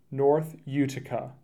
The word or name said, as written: North Uticah